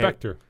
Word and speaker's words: Spectre: Yeah, you're saying it right. Okay. Spectre.